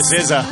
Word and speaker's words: SZA: Ben oui, César. S-Z-A, si vous l'aviez déjà vu, c'est comme ça que ça se prononce, César.